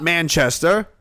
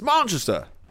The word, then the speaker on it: Manchester: It's not Manchester. It's Manchester.